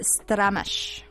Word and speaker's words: stramash: Oh, no, I'm saying it wrong. It's a stramash.